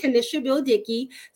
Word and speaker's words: Kenisha Beal-Dickey: The way you pronounce my name, by the way, for everybody out there, I know it's long, it's like a sentence, but but it's Kenisha Beal-Dickey.